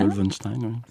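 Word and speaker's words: Lol V. Stein: Lowell Weinstein, oui.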